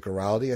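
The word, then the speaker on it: Guaraldi: And also when you hear, when you listen to like the Christmas music and you just hear the Vince, was it Giraldi? I think it's pronounced.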